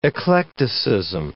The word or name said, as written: eclecticism